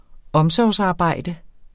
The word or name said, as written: omsorgsarbejde